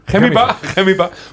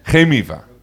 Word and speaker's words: Gemiva: Van, en nou komt het, Gemiva, wat was het nou in het Spaans? – Gemiva. – Hoe spreek ik jullie mooie stichting uit? – Gemiva.